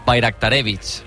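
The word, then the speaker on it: Bajraktarević: Bayraktarevich. – Bayraktarevich. O sea, la J la convierte en una I, Bayraktarevich.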